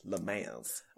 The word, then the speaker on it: Le Mans: L-E-M-A-N-S. Le Mans.